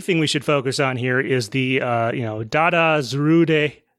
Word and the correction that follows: Zarud: Zarud. I keep wanting to say it at three syllables. It is only two.